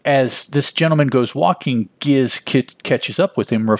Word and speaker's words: Giz: We find out that this is Giz and Dave, and I will pronounce it Giz because it's a G-I-Z. Hard G because soft G just sounds very yucky here, so I'll go with Giz.